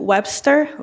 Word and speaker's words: Webster: Yes, my name is Charlene Webster. Last name is spelled W-E-B-S-T-E-R.